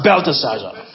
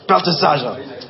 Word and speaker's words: Belteshazzar: I probably did not pronounce it. Belteshazzar. Belteshazzar. How you say it? – Belteshazzar. – Belteshazzar. All right. Yeah. I gotta fix my face and say it properly.